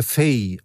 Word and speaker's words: Faye: Also der französische Publizist, den Sie zitiert haben in Ihrem Buch, Herr Fay, oder ich weiß nicht, wie er ausgesprochen wird, F-A-Y-E.